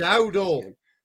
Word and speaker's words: Dowdle: The Waddle, or however you pronounce it. – Dowdle.